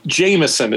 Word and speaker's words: Jameson: We pronounce it wrong. Jameson is what we say in America.